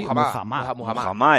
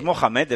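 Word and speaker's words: Muhammad: No es Mohamed, es que no es Mohamed Ali. Sí que hubo un momento que se le llamaba Mohamed Ali, pero no era... No es, es Mujamá.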